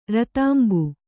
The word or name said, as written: ರತಾಂಬು